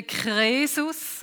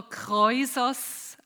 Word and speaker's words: Krösus: Und der Krösus, das war der König, der Krösus auf Griechisch oder Kreusos auch ausgesprochen. Wir sagen dem Krösus.